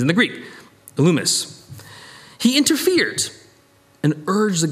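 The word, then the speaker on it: Elymas: That's how you say it in the Greek. I know it looks like it's Elimus, but it's Elumus, which sounds like illuminate, which is what the word means.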